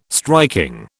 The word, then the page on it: striking: British English